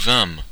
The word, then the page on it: vînmes: /vɛ̃m/; France (Normandie)